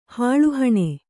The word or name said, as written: ಹಾಳುಹಣೆ